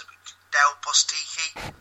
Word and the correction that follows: Del Bosque: Del Bosque, oh, yeah, I accept that.